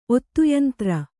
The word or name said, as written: ಒತ್ತುಯಂತ್ರ